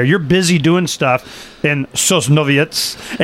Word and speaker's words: Sosnowiec: I'm from Sosnowiec. – Sosnowiec. Sosnowiec. Said it wrong. Okay, yes. I'll just make sure you say it right.